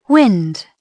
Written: wind

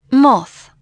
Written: moth

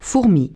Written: ant

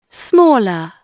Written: smaller

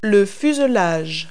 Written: fuselage

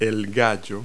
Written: rooster

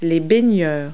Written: swimmers

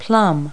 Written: plum

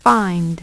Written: Find

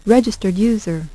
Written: registered user

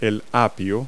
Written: celery

